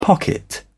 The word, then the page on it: pocket: British English